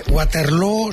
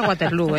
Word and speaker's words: Waterloo: Pues no lo es, desde luego, pero Abba ha hecho mucho daño en la pronunciación de Waterloo, que se pronuncia igual que Feijóo.